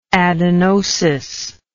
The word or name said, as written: adenosis